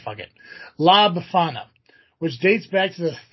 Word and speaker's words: La Befana: La Befana, meaning old witch. I'm saying that right. La Befana, yeah.